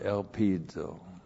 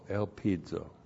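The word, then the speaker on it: elpizo (ἐλπίζω): And absolutely not as, and then the verb elpizo. E-L-P-I-Z long O, elpizo.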